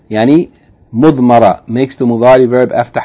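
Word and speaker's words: Mudmara (مضمرة): Yani, mudmara is spelt meem, bad, meem, ra, and tarmabuta.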